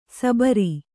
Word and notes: ಸಬರಿ: sabari

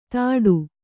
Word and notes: ಥಾಟು: kannada

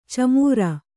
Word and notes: ಛೀಮಾರಿ: Kannada

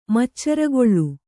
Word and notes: ಮಚ್ಚರಗೊಳ್ಳು: Kannada

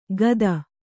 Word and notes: ಗದ: gada

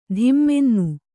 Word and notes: ಧಿಮ್ಮೆನ್ನು: kannada

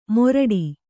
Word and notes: ಮೊರಡಿ: moraḍi

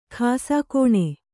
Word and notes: ಖಾಸಾಕೋಣೆ: khāsā kōṇe

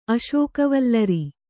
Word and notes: ಅಶೋಕವಲ್ಲರಿ: kannada

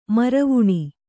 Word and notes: ಮರವಣೆ: maravaṇe

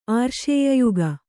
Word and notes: ಆರ್ಷೇಯಯುಗ: ārṣēyayuga

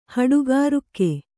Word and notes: ಹಡುಗಾರುಕ್ಕೆ: Kannada

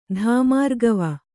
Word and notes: ಧಾಮಾರ್ಗವ: Kannada; dhāmārgava

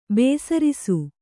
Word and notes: ಬೇಸರಿಸು: bēsarisu

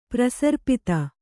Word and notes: ಪ್ರಸರ್ಪಿತ: prasarpita

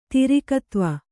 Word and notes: ತಿರಿಕತ್ವ: Kannada

